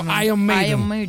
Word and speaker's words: Iron Maiden: Come per esempio Iron Maiden, che noi facciamo Iron, la R non si pronuncia quasi, è tipo Iron Maiden, senza R, teoricamente, nella pronuncia corretta, British, chiaramente.